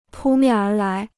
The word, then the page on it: 扑面而来: pū miàn ér lái